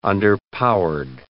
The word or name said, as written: underpowered